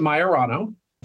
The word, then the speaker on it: Maiorano: Yeah, it's, it's a very common issue I've run into pretty much my whole life, but I pronounce it Maiorano. The little trick I like to give people in the hospital is he's not your Rono, he's my Rono. That usually helps them uh, get the cadence right.